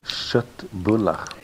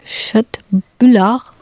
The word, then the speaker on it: Köttbullar: And I think it's called Chötbular. How do you pronounce it correctly? – Köttbullar. – Köttbullar. – Köttbullar med lingon.